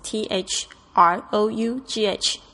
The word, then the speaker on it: through: T h r o u g h，注意一下 t h 的发音的声音。 Through，然后呢 g h 这边没有发音。